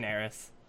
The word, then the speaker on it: Chinaris: How do you pronounce Chinaris. – It? – Chinaris. – Oh, Chinaris. Okay.